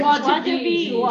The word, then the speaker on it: joie de vivre: Joie de vivre.